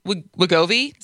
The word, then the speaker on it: Wegovy: and Wegovy.